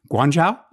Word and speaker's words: Guangzhou: I mean, it was in Guangzhou.